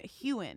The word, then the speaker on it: Heughan: Hewin? Heughan. – Heughan.